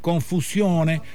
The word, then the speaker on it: confusione: Y si querés decir confusione, acordate de que la O de sione, sione, es fuerte, se pronuncia mucho y la E final tiene mucha presencia. No se parece al francés como suena en el programa.